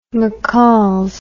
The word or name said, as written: mccalls